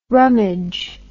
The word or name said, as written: rummidge